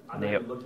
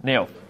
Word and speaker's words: Naioth: Okay, how do you say that word? Naoth? Naoth? – Naoth. – N-A-I-O-T-H. – N-A-I-O-T-H. – How would... Naoth? Just Naoth. Okay, good. – I would say that. – Perfect. Naoth.